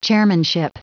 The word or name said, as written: chairmanship